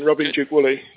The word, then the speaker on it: Duke-Woolley: And you're perfectly right with the pronunciation, Robin Duke-Woolley.